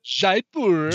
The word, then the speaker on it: Jaipur: The game is called, I think I'm saying this right, Jaipur? Is that how you'd say it? – Jaipur. – Jaipur.